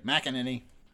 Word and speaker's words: McEnany: It's McEnany. I want to say McEnany, but okay, McEnany.